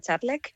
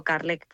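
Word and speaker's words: Kärlek: Y de hecho se pronuncia Charlek, pero sí, para la comodidad de la gente siempre les digo Carlek, pues para que les sea más fácil. – Claro. – Al final, al hablarlo en el español, pues es así la pronunciación, ¿no? – Bueno, pues si lo pronunciamos bien es Charlek y eso es.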